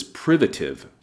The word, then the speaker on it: privative: Notice the accent is on the first syllable like the word primitive, but the word is privative.